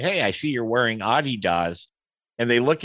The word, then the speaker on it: Adidas: He's worked with Callaway and TaylorMade and Adidas, or I should say it right, Adidas. Isn't that correct? – That's correct. That's how it's pronounced in Germany.